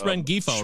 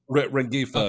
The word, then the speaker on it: Rengifo: So I really like Luis Renfro. If you need... – You're talking about Luis Rengifo, right? Is that what you're saying? – Rengifo, okay. Yes. I do not pronounce the G.